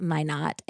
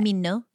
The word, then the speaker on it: Minot: And she was like, just so you know, it's spelled like why not Minot. – Okay. – Not um, pronounced, sorry, not, pronounced like Minot, why not? Minno. – Not Minno. Minno. North Dakota.